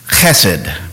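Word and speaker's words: chesed (חסד): Look at that word, chesed. It's a Hebrew word. There's a little soft guttural sound in the back of the throat. Phonetically, you might say chesed, like cheese or chase.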